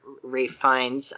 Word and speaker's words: Ralph Fiennes: Ralph Fiennes or Ray Fiennes.